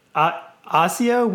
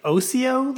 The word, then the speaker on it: Osio: And then Ozio, we say Ozio Labs, O-S-I-O.